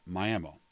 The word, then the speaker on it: Miami: Now, while it's spelled the same as the much larger town you may be familiar with in Miami, Florida, in Okie speak, around here, our town's name is pronounced Miami.